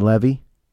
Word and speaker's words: Levy: Eugene Levy.